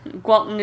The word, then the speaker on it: Gwaltney: So, Gwaltney. It's like Waltney, but with a G. – It's like if you're not good at saying Walt Disney. – Gwaltney Disney.